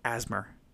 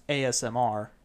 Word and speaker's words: ASMR: Asthma. and then your shuffling can be all asthma and be all perfect. It's it's pronounced ASMR.